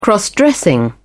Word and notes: cross dressing: British English

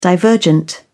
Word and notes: divergent: British English

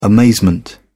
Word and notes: amazement: British English

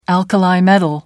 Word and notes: alkali metal: British English

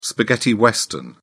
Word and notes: spaghetti western: British English

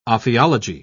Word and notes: ophiology: British English

